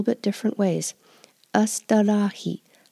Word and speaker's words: astârâhi: Tata kita astarahi.